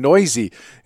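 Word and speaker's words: Neuse: Sheldon Noisy is apparently how it's pronounced.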